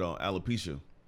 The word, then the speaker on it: alopecia: And she's actually battling with alopecia.